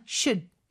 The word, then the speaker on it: should: 그리고 should는요. 그냥 should 이렇게 짧게 발음되는 경우도 많아요. L 발음 절대 하면 안 되고요.